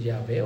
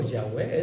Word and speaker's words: Yahweh: desde el momento en que inhalamos el nombre de Yahvé o Yahweh, es así se pronuncia,